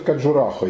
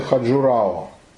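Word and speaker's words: Каджурахо (खजुराहो): Они произносят Каджурахо, европейцы Хаджурао.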